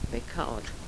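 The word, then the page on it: bekar: dialetto croato di Lukezi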